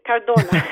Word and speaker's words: Cardona: Let's say that it's okay. Cardona.